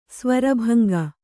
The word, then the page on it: ಸ್ವರಭಂಗ: svara bhanga